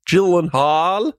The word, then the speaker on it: Gyllenhaal: Ist ja ein estländischer Name. Gyllenhaal. Ist wirklich so. Hat er mal irgendwann bei Conan erzählt.